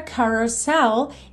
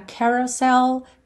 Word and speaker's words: carousel: Seguindo aí, you're a carousel. E esse começo aí pode ser mais ca ou mais ca, como você preferir, tá? Carousel, carousel, ambas as pronúncias estão corretas.